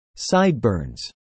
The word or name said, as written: sideburns